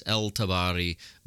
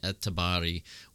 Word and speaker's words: al-Tabari: It's written as al-Tabari, but in Arabic the al is not pronounced.